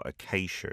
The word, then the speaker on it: Acacia: Solomon with Acacia, or Acacia as we pronounce it in English.